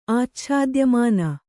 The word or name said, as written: ಆಚ್ಛಾದ್ಯಮಾನ